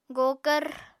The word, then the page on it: gokvr: Apatani